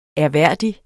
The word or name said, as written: ærværdig